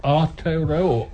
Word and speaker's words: Aotearoa: How do you pronounce... Is it Aotearoa? – So it's R or O. Aotearoa. There were some people just talking about whether it's going to be Aotearoa or Aotearoa.